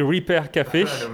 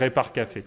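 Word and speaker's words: Repair Café: Alors en principe, on dit Repart Café à l'anglaise, mais nous, on va dire Repart Café.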